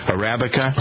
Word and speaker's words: Arabica: USDA certified organic coffee, shade grown coffee, top Arabica grade. Why is it so hard for me to pronounce that? Arabica.